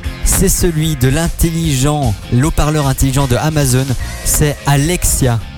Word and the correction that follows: Alexa: Alexa. – Alexa.